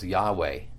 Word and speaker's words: Yahweh: But in the Hebrew, it's often pronounced Yahweh, because the J in Hebrew is pronounced like a Y. That's why it's Yahweh. Or if it were Jehovah, it would be Yehovah. But they would probably pronounce it Yahweh. So it's Yahweh, God of your fathers.